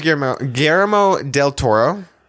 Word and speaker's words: Guillermo del Toro: And the director is Guillermo del Toro.